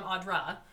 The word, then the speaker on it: Audra: And then call me like Audrey. Like all my whole life people have mispronounced my name. – Wait. So I'm Audra.